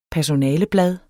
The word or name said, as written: PERSONALEBLADE